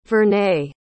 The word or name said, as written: vernay